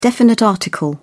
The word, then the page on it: definite article: British English